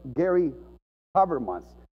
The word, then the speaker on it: Gary Habermas: Ahora, un doctor, este doctor Gary Habermas, se pronuncia así, ha documentado un montón de casos, hermanos, y ha estudiado cientos, si no miles de casos, de experiencias cercanas a la muerte.